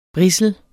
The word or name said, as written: brissel